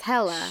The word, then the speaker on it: Helle: How do you spell it? – H-E-L-L-E. – Helle? – Hella. – Hella? – Hella. – Whatever. Okay. – I'm telling you, she's Danish, okay? – So they pronounce the E with an, like an A? – That is how it was pronounced.